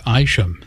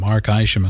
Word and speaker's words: Isham: Two tracks there from Mark Isham. That's spelled I-S-H-A-M, Mark Isham.